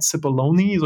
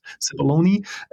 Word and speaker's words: Cipollone: Das wichtigste Beispiel ist tatsächlich dieser von dir schon angesprochene Pat Cipollone, so spricht man ihn, glaube ich, aus, Cipollone, ähm,